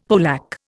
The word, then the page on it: polack: Indian